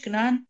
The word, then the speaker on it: Canaan: How do you say in English? Canaan. – Canaan. Yeah, the Quran. – Can- no, no, Canaan, the land of Israel.